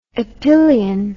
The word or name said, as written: epyllion